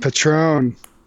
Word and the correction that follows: Patreon: Come on, it's Patreon. Get it right.